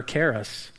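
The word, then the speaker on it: charis (χάρις): The only problem is, in the original language, the C or the K is silent, and it's actually the word charis. So in the Greek language, we say charis, that's grace, but it's really charis.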